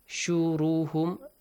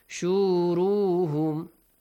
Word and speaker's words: شُرُهُمْ: Make sure you do not pronounce شُرُهُمْ with a long vowel. شُرُهُمْ. وَيَوْمَ نَحْشُرُهُمْ. No. وَيَوْمَ نَحْشُرُهُمْ. Short vowel, okay?